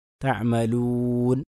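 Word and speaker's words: تعملون: അതിന് ശരിയായ ഉച്ചാരണം തഅമലൂൻ, വാവിനെ ശരിക്കും ചേർത്തിക്കൊണ്ട്, ചുണ്ട് ചേർത്തിക്കൊണ്ട് തന്നെ പറയണം. അപ്പോൾ അതാണ് ഒന്നാമത്തെ കാര്യം.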